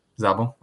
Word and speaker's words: Szabo: Nick, I think Szabo, I'm not sure how to say his last name. – Szabo, okay.